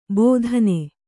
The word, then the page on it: ಬೋಧನೆ: bōdhane